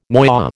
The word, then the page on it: noyau: American English